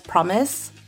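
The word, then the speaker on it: fromis: Yeah, but it's pronounced as Promise, because they have a promise to their fans that they'll be the best girl group. Cute! – Oh, so the F nice. Has a P sound. – Promise. Yeah, it's like a play on words. – Oh. Well, it's also because there's no F in Hangul.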